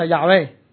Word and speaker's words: Yahweh: 希伯来原文呢嘅读音就 Yahweh。